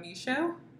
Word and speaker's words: Michaud: My name is Cynthia Michaud, M-I-C-H-A-U-D. I have a hard last name. – Silent D.